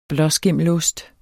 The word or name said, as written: blåskimmelost